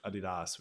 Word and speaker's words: Adidas: Yeah, as a certified Slav, I have to say everyone should own a pair of Adidas, which is the correct way to say it, of course.